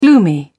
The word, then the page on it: gloomy: British English